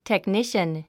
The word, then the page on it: technician: tekníʃən; テクニシャン